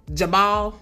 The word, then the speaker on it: Jabal: Jabal, okay, and Jubal. J-A-B-A-L and J-U-B-A-L.